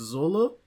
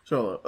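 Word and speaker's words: Xolo: but I think it's Zolo? – Sholo.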